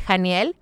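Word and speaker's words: Haniel: También cuando estemos buscando trabajo, Arcángel Janiel, se escribe con H, H-A-N-I-E-L, H-A-N-I-E-L, Janiel, pero se pronuncia con J, como J, y nos ayuda también en las pruebas importantes que tenemos en la vida.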